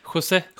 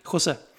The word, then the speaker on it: José: Eller José, Jose eller hur han vill att man skulle uttala nu igen. José. José.